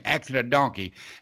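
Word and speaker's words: Actin' a Donkey: They actually, it's It's called Acting a Donkey, but it's spelled A-C-T-I-N, Acting a Donkey.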